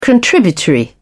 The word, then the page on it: contributory: British English